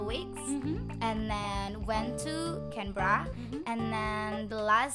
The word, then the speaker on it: Canberra: But the correct pronunciation is supposed to be Canberra.